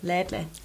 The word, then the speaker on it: Lädle: Alles schön und gut, aber äh, genau, das war eine Situation aus dem, aus dem Lädele. – Lädle. Aus dem Lädle. Lädle, habe ich das richtig gesagt? Lädle, ja. Tante Emma Lädle.